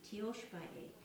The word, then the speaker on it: Tiyospaye: It's called Tioshpae, and it's spelled T-I-Y-O-S-P-A-Y-E. Tioshpae.